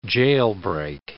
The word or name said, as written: jailbreak